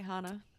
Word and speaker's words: Hana: a town called Hana or Hannah. I don't know how you pronounce it. – Yeah, you're right, Hana.